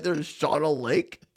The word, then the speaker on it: Shawano: S-H-A-W-A-N-O. How do you pronounce it? – Shawano? – I say Shawano. – Do you know that my dad and stepmom live there? – Yeah, I didn't know that, but they live in Shawano because sorry, I'm a child of phonics. – No, it's pronounced Shawano.